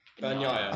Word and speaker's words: Bagnaia: Let me pronounce this right. – Oh gosh, Francesco. – Yes. – Bagnani. – Bagnaia. – I'll tell you what. Well done on the first name.